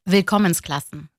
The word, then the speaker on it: Willkommensklassen: telaffuzunu söyleyeyim bana. – Willkommensklassen. – Ha, Willkommensklassen. Benimki olmamış, evet.